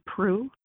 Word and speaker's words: Proulx: Social media, I'm under Natalie Prue -facingthesun. And my last name is spelled Proulx.